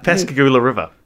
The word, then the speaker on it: Pascagoula: This sighting was only the beginning of what was to occur the following night on the nearby Pascagoula River. Did I change that pronunciation again? – No, you're right. Pascagoula River.